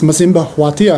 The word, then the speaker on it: Masimba Hwati: I'm here with Masimba Hwati.